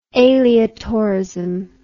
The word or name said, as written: aleatorism